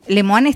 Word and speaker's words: Lemoine: Lemoine está bien pronunciado.